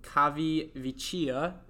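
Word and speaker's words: Civitavecchia: bombings were scheduled in the German-controlled city of Cavi Vecchia, Italy. Did I say that right, you think? – Sounds right.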